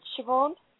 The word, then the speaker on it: Siobhan: Yeah, it's Siobhan.